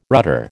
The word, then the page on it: rudder: American English